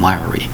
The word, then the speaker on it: wiry: Is that person wiry? What does that mean by wiry? Well, think about the word wire. Add a Y. W-I-R-Y. Wiry. Two syllables.